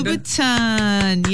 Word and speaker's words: Louboutin: Louboutin, is that what you said? Look at you. – Did I say it right? – Christian Louboutin. Yes, indeed.